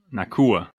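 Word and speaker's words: Nacua: Pronounce that last name for me there. – Nakua.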